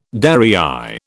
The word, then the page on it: derrière: British English